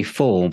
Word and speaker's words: Fahl: Yes, for all of us in the UK, it's Mary Fall, but it's spelt F-A-H-L, in case you go and Google and they can't find you straight away.